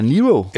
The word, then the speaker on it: Nyro: at det skulle udtales Laura Nero.